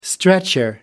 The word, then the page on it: stretcher: American English